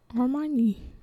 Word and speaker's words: Hermione: They're probably thinking about what's her name, Hermione. That's how you say it.